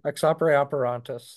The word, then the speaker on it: ex opere operantis: Yeah, ex operatist. – Yes. – I think you're not pronouncing it right, but it's okay. – Oh yes, you're right on. Ex opere operantis.